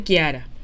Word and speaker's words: Chiara: Se llama, o sea, se escribe Chiara, pero se dice Kiara.